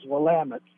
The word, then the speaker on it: Willamette: It became anglicized as Willamette.